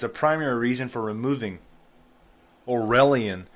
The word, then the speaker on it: Aurelian: Yale University has banned the Aurelian Honor Society. Excuse me, Aurelian. I pronounced it wrong.